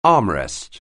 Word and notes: armrest: anglais

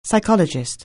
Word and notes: psychologist: anglais

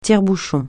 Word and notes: tire-bouchon: francais

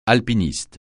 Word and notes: alpiniste: francais

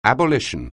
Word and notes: abolition: anglais

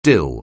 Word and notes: dill: anglais

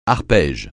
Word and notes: arpège: francais

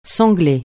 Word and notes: sangler: francais

